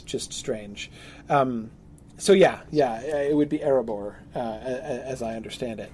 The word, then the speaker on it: Erebor: Now with Erebor, it is more air, like A-I-R. A-I-R, yes, air. Yeah. Yes, because that would be the long, the long E there. Yeah. So it's definitely not ear, because the E sound is associated with I, not with E.